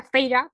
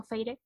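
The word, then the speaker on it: Feyre: Ah, a una saga, eh, compuesta por los libros que ya dije antes, y se trata de una chica que se llama Feire, aunque se pronuncia Feira, pero yo le digo Feire porque uruguaya, arre.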